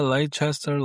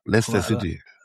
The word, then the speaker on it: Leicester: Leicester, Leicester, Leicester. Leicester City.